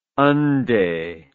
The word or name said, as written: unde